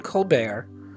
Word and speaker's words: Colbert: Oh, everybody needs to Google Stephen Colbert. And I don't remember how that's spelled, but there's a silent T, I think, at the end.